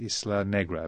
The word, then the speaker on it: Isla Negra: and one at Isla Negra. Have I said that right? – Right.